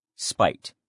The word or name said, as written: spite